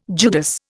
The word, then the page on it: Judas: Indian